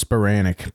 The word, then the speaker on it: Spiranak: Am I saying that right? Spiranak? – I believe it's Spiranak, but a lot of people.